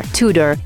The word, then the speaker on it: Tudor: Tudor, you might think you'll say Tudor. It's exactly as it's written, T-U-D-O-R, Tudor. It's actually not, it's Tudor, Tudor.